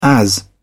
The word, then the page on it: as: British English